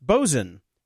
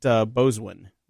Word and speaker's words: boatswain: Christopher writes in, I believe boatswain is pronounced bosun, as in bosun whistle. But I call it boswin, not right. Bosun. Okay, so I was close.